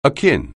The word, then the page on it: akin: English